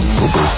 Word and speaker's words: pupu: Apparently, this is called P-U-P-U. Apparently, it's pronounced. I didn't want to believe it was the case. Apparently, it is pronounced poo-poo.